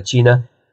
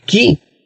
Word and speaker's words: Qi: El Qi, no es el Chi, sino el Qi, se pronuncia Qi en chino, existe y ustedes no se imaginan la fuerza que uno puede llegar a alcanzar utilizando el Qi.